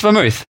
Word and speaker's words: vermouth: Vermouth? Vermouth? Vermouth? Depends, but in English, it's vermouth.